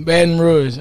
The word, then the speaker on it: Baton Rouge: Is that say right? Baton Rouge? How do you say Baton Rouge. – Baton Rouge. Rouge. All right, yep.